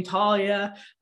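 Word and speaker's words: Thalia: As they're trying to get the kids, Percy's feeling all these insecurities about Talia. And that's how you pronounce her name, Talia.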